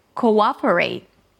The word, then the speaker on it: cooperate: The second word, cooperate, has four syllables.